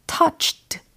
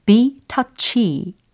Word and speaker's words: touchy: 근데 여기서 주의해야 될 것은 touched 라고 발음을 하면 안 되는 단어였다는 거예요. 어, touched 는 PP형이 되는 거죠. 뒤에 ED로 끝나니까. 만약에 be touched 라고 했다면 감동받다가 되는 거죠. 근데 오늘은 be touchy 라는 발음이었고 철자도 맨 끝에 y로 끝났다라는 것을 비교해 두시면 되겠습니다.